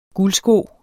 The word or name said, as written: guldsko